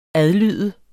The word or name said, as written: adlyde